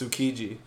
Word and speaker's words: Tsukiji: I would say you don't pronounce the T. – No. – So it's Tsukiji.